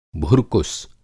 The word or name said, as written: भुरकुस